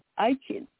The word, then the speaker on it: Aichen: What you can do, like, just hold up your index finger, point to your eye, and then eye chin. Just eye like chin.